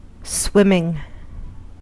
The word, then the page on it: swimming: American English